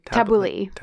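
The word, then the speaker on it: tabbouleh: Tabouli. Okay, that's how I learned how to say it. Because in French, you say tabouli. So I assume. – That's close enough. – I'm assuming it's not that far off in English.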